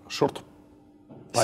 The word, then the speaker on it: Shortparis: Надо для всех, короче, зафиксировать, что правильно говорить шорт Парис. Сегодня вечером шорт Парис.